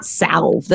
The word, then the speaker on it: salve: You- it's not salve. The L is silent, right? Salve.